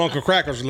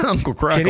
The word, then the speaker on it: Uncle Kracker: I'm on Uncle Cracker's level now. I almost couldn't say that right. I said Crackle. – Uncle Crackle.